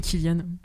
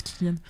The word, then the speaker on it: Cillian: Avec un C, mais ça se prononce Cillian.